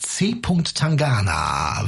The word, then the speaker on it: C. Tangana: C. Tangana.